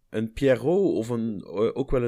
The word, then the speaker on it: Pierrot: Ja, eh, dan, hebben, volgens mij spreek je het uit dus als Pierrot. Ja, Pierrot.